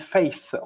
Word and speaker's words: Faith: Eh bien, vous êtes toujours à l'écoute du Gros Bazar et vous écoutiez donc Iso Fitzroy avec Blind Faith. On l'a mal prononcé tout à l'heure, c'est Faith, la foi.